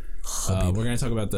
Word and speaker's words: Khabib: Khabib. Is it Khabib or Khabib? – I think it's like Khabib. It's definitely Khabib.